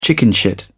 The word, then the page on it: chickenshit: British English